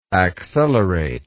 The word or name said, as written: acceleratedly